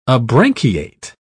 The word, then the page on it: abranchiate: British English